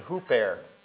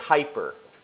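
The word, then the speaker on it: huper (ὑπέρ): This preposition that he uses here that's translated for is the preposition huper. Okay? We get, in English, we pronounce this U as a, this upsilon as a Y, and so we say hyper.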